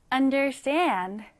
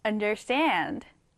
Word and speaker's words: understand: 단어가 조금 길지만 보이는 그대로 발음됩니다. understand. 같이 한번 써볼게요. 자, 어떻게 쓰죠? you N, D, E, R, S, T, A, N, D. 읽을 때는 understand 뒤에 이렇게 강세를 주시면 됩니다.